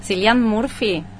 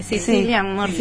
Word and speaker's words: Cillian Murphy: Cillian Murphy. – Sí, perfecto. – Cillian Murphy.